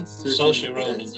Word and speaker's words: Saoirse Ronan: Saoirse Ronan, yeah.